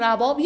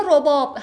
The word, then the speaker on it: رباب: مولانا به شدت این رباب یا رباب، هر دو رو میگن، هر دو تلفظ درسته، ولی قدما گویا بیشتر رباب میگفتن. مولانا خیلی جاها رباب رو با کباب مثلا هم قافیه کرده، از این جاها ما میفهمیم که اونا میگفتن رباب، ولی ما بیشتر الان میگیم رباب. خیلی فرق نداره، جفتش دوتا تلفظ از یک کلمه.